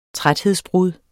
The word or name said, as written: træthedsbrud